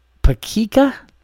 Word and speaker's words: Pecika: I'm sorry if I butcher the name, but the name is uh Taylor Pecika is P E C I K A